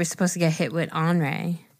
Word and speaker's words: Henri: Remember, it looks like Henry. The word looks like Henry, but it's pronounced Henri.